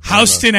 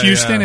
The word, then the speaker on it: Houston: Houston Avenue. Like, yeah. Show off that it's not pronounced Houston. Yeah. It's pronounced Houston up there.